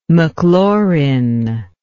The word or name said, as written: maclaurin